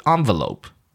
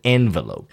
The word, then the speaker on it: envelope: And some people in Britain would pronounce it envelope. Okay, 佩蒂知道吗？英式的发音啊，一个信封有没有？叫 envelope. 它那个 en 哦，是 on, 是一个欧洲的发音，是法文音。然后呢，美国人呢就是 envelope.